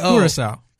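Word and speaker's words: Curaçao: So in honor of Josh Hader, during the wild card series, which contains lemonade, blue, is it Curacao? How do we pronounce that? Hey, you drink all the time. Is it C-U-R-A-C-A-O? – Curacao. – Curacao, okay.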